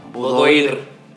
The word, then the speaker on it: boudoir: Boudoir.